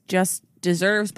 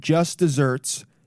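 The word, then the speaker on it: just deserts: despite its pronunciation, just desserts. Oh, it has one S.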